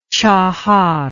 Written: qahar

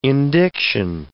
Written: indiction

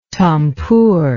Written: tampur

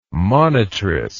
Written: monitress

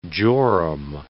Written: jorum

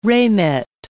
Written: ramet

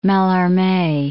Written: Mallarme

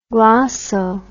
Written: glosso

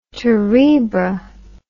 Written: terebra

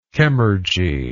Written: chemurgy

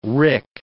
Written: rick

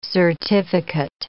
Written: certificate

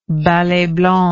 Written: ballet blanc

